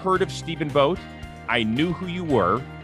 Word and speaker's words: Vogt: Steve is Vogt, the only guy with a silent T in the world to his name.